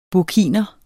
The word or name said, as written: burkiner